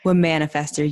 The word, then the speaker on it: Womanifester: Well, hello, Womanifester. Am I saying that right? – Womanifester. Yeah, like there you go. You just gotta commit to it.